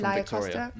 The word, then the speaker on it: Laia Costa: And Laia Costa, is that how you say her name? – Laia Costa.